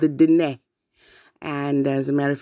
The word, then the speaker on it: Diné: And so you say it looking up to the sky, Diné, which will give you the proper pronunciation of that tribe.